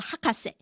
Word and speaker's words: 博士: 其实不是哦，这个博士呢这个字哦，这两个字念作这个学位的那个博士 Master 的时候呢是念作 Hakase。